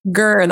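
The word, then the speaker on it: ger: or you pronounce them gur. How do you pronounce them? Gur? – Gur. That's a Mongolian word. Yeah. Gur.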